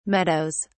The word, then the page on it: meadows: British English